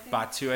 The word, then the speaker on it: Batuu: Batuu, I think is how you're supposed to pronounce it.